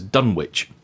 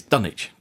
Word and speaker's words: Dunwich: I'd always referred to it as Dunwich. That's how it's pronounced in the 1970s film and every other version of media that I've seen regarding this. The real Dunwich has a very different pronunciation. It is Dunwich.